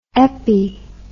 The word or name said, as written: epi